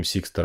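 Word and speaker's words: Sixto: В общем, история в том, что в 70-х годах в Детройте жил такой Сиксто, я правильно говорю? Сиксто, да.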